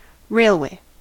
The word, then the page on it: railway: American English